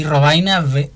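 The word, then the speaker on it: Rovaina: En el Facebook me pueden conseguir como Masajista Paraná o como Tomás Robaina, que se escribe TH, uh-huh. el nombre, y Robaina, R-O-V-Corta.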